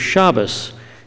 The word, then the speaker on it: Shabbos: The word Shabbat is how you say the Hebrew word in the Sephardic Jewish accent. You'll hear it also pronounced as Shabbos in the Ashkenazi Jewish accent.